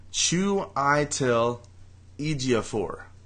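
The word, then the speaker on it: Chiwetel Ejiofor: And his first name is pronounced Chu Itel Ejiofor. That's his whole name. – What? – Chu Itel Ejiofor.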